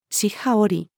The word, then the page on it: 尻端折り: female